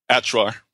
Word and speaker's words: Achuar: Achuar and back. So I'm sure a couple things were lost in translation as well. – What's it called? Achuar? – Achuar, yeah.